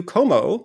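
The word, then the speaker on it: Comeau: Como. My last name is spelled C-O-M-E-A-U. I like to tell people that my name ends in 3 vowels and is pronounced like none of them, which is fun.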